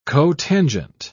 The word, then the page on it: cotangent: British English